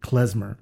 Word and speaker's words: Klezmer: Klezmer.